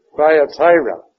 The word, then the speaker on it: Thyatira: And the next one, I would like to hear what you have to say, but Thyatira. Yeah. Thyatira. Yeah. We, we would probably say Thyatira, but that is not the way they pronounced it.